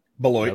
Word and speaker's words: Beloit: Beloit. – Beloit? How do you say it? It's Beloit.